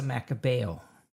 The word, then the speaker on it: Macabeo: And I'm going to let you pronounce it. – It's a Macabeo.